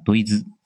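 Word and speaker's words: ドイツ: 德国呢，在日语里面的发音叫德意兹，所以呢，经常被俄国和德国暴打的波兰人呢，被送外号叫平毒正路大波波。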